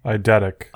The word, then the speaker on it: eidetic: is it eidetic memory or eidetic memory? How do you pronounce it? Eidetic memory? – Eidetic. – Eidetic memory. There we go.